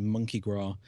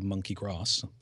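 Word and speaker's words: Monki Gras: Well, some of our colleagues from Texas are here and have been at the event as well, and they keep insisting it's called Monkey Grass. But no, it's Gras. – I think the S is silent. – So. Mardi Gras.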